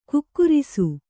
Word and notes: ಖೇಕರಿಸು: khēkarisu

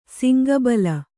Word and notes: ಸುಂಕುಬುಲ್ಲು: sunkubullu

